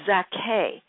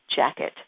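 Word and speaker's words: Jacquet: In the beginning, there was the wonder of Illinois Zaké, which is really the pronunciation of his name, but people know him as Illinois Jacket.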